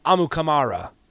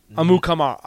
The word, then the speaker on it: Amukamara: And once again, his last name, if you want to officially pronounce it as we heard it on the captain, it's Amukamara. Amukamara.